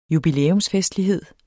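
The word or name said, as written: jubilæumsfestlighed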